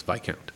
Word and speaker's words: Viscount: Because it's spelled V-I-S-C-O. – Yeah, and you Viscount. Would, like, for us as Americans, we would pronounce it that way, but because it's British, it's pronounced differently, and it's Viscount.